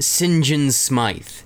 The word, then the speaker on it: St. John Smythe: Here's what's crazy about it is that it's spelled St. John Smith, but it's pronounced St. John Smythe.